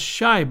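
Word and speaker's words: Chi: Chai, pronounced shy, by the way, is the argot for Chicago.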